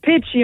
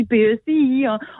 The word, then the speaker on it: Pecci: Nous, on le prononce Pecci un petit peu à l'italienne, là. mais on peut le prononcer aussi PECI. On laisse les gens aller là, comme ils veulent avec l'interprétation de ce titre d'album-là.